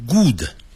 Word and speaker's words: good: good 不要念成 good，叫 good， good 跟书本那个 book 的发音是一样的， book good 元音都是一样的啊。